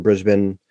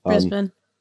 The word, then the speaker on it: Brisbane: And they're looking at things like putting a hotel in Brisbane or Brisbane. I'm sure I said that wrong. – Brisbane.